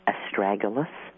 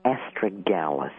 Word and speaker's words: astragalus: And it goes by the name of astragalus or astragalus. You'll hear both pronunciations.